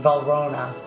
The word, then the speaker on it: Valrhona: How do you say this world? Valrhona chocolate. Is that how you say it? – Yes.